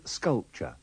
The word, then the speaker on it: sculpture: Pay attention to the pronunciation of the T-U-R-E in sculpture. It is like the T-C-H of watch with a schwa on the end.